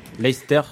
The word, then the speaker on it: Leicester: Ben, Leicester, il montre que finalement, quand t'as... – Leicester. Tu connais ce club, Leicester. – Il prononce en anglais.